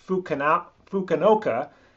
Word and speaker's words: Fukuoka: There's an airport in Shanghai that goes by S-I-N or Sin. And then there's one in Japan's, I think the city is pronounced Fukuoka. And they go by the first three letters of that city's name, which is F-U-K.